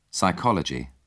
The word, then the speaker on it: psychology: Psychology. In English, the initial P has fallen silent, and only the S is pronounced.